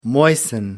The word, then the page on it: moisten: American English